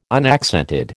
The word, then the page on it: unaccented: American English